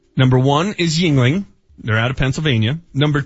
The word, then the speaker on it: Yuengling: That allowed Yuengling, is that how you pronounce it? – Yuengling, to now qualify as a craft brewery.